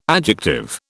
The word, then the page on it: adjective: British English